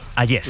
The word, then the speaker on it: Hayez: Vienes a descubrirnos el cuadro El beso de Hayez, se pronuncia, ¿no? – Hayez. – Podríamos decir. Francesco Hayez.